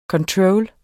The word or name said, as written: ctrl